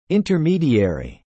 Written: intermediary

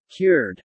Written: cured

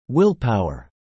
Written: willpower

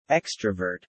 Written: Extravert

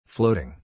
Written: floating

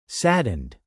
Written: saddened

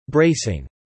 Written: bracing

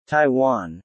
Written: Taiwan

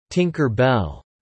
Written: Tinker bell

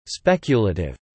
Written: speculative

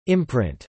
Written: imprint

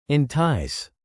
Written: entice

